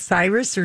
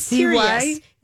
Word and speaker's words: Sirius: Is it Cyrus or Sirius? – It's or? – Sirius. – Is it Sirius? – No, it's S-I. It's Sirius. Oh. It's like Sirius Radio.